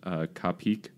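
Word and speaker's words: qapik: The internet told me it was pronounced kapik. It's Q-A-P-I-K.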